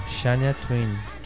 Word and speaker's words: Shania Twain: Y número uno, Shania Twain.